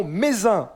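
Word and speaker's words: Mézenc: Le point culminant, c'est le mont Mézin. On ne prononce pas le C à la fin. 1753 mètres d'altitude pour le mont Mézin.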